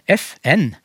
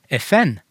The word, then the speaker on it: FN: FN, da sier du det som om det var to ord, med trykk på begge ordene. Det blir omtrent som jeg sier 'god mat'. Eh, sånn uttaler han FN. Mens eh, min uttale, FN, det er omtrent som når jeg sier peisved. Eh, og det jeg gjør da, er å bruke tonelag.